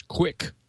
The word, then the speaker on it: QUIC: It's an acronym, which we are to pronounce QUIC, of course.